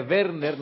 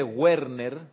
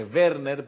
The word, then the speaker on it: Werner: Werner Schröder, se dice Werner, no se dice Werner, se dice Werner porque es un nombre en alemán, en alemán la B.